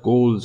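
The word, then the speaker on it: Ghouls: ولی من شنیدم گول اما در به هر روی در این شعر باید گول تلفظ بشه تا در واقع به اختزای قافیه و ما همینجوری خواهیم خوند They are Gauls,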